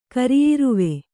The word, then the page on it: ಕರಿಯಿರುವೆ: kariyiruve